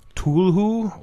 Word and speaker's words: Cthulhu: I say Cthulhu. – Whatever's more pretentious. That's what I choose. – Think I read somewhere that, like, Lovecraft had intended it to be pronounced like Tulhu, or like without the C.